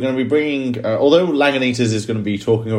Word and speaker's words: Lagunitas: Is that how I pronounce it? – Yeah, well, I pronounce it Lagunitas. – Either way. – So I think the same. – Absolutely nailed it. – Well, I think we pronounce it the same.